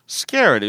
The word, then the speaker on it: scared: scared 요거 발음할 때 s 다음에 크 c나 k 발음 나오면 끄 이렇게 발음이 많이 돼요. 끄크 이렇게 발음 잘 안 되고 음. I'm so scared 이렇게 잘 안 해요. 예. I'm so scared 이런 식으로 끄 발음 비슷하게 난다는 것 좀 알아주세요.